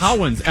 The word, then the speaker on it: Cowens: Rosie was on the right check, but he pronounced it wrong. – What? – He what? – Said like Collings. Al Cowens. Al Collings.